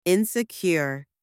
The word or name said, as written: insecure